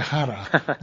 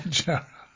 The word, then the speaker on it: Jara: Yeah, and mispronounced by me, Victor Jara, not Jara.